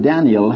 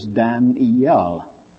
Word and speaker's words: Daniel: The Hebrew word Daniel has three syllables, Dan-iel. The English word, two, Daniel. But the American in those days, only one. Have you ever heard of Daniel Boone? Have you ever heard of Daniel Webster? That's how they spelled it, D-A-N apostrophe L, and they pronounced it as one syllable.